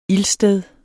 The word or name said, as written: ildsted